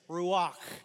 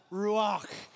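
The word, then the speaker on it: ruach (רוח): Ruach. Like, you gotta say it like you're hawking a loogie. Like, if you don't, you're not saying it right. All right, it's ruach.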